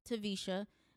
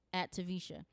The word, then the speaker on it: Taversha: And social media is @Tavisha.